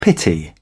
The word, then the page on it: pity: British English